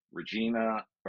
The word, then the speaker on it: Regina: And we went everywhere from Regina to Rhymes with Fun.